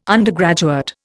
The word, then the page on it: undergraduate: Indian